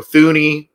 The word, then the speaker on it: Thuney: Joe Thuney got, or Thuney, however you want to pronounce his name, got the franchise tag with the Patriots.